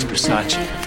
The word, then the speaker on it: Versace: It's pronounced Versace.